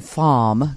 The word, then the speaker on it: Femme: Or I would like to say FOMM, because it's F-E-M-M-E, the French pronunciation.